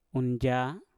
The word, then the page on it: unja: mpur